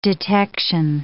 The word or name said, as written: detection